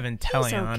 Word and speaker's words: Inteleon: I have Inteleon.